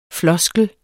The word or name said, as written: FLOSKLEN